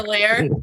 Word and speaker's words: Chevalier: Okay, no, that is the English pronunciation. – Chevalier. – Chevalier. It's Chevalier. – It is a French word.